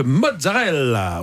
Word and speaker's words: mozzarella: Loana Belmondo, on parle de mozzarella aujourd'hui. Déjà, est-ce qu'on prononce bien ? C'est mozzarella ? – Mozzarella. – Mozzarella. – Vous le dites très très bien.